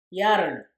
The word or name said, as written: yarrern